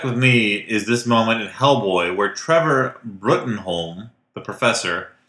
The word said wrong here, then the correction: Bruttenholm: Pronounced Broom.